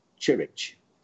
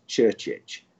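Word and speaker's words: Saša Ćurčić: So they said, we're asking, how would you pronounce Sasa Chirich? So I said, no, it's Churchich.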